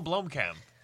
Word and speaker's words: Blomkamp: I'd like to point out that David pronounced it Blumkin movie rather than Blumhouse. What? – Blumkin. Is it Blumkin? – Blumkin. – District 9? – Oh, Neil Blomkamp.